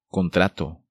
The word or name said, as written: Contrato